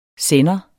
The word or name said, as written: sender